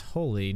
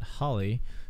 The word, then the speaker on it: Holi: I also mispronounced the Indian holiday. I think it's Holi, not Holly.